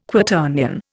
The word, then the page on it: Quaternion: Indian